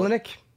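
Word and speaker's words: Oleinik: Pronounce that name for me. Who we fought. – Olenek? – Yeah, Alexei Olenek.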